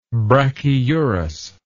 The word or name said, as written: brachyurous